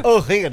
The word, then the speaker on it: O'Higgins: O O'Higgins. – O'Higgins. – Aí. – Falei certo? O'Higgins. – Aí. – O'Higgins.